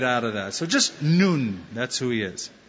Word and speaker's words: Nun: We pronounce it Nun, but it's in Hebrew, Nun.